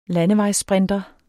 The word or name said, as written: landevejssprinter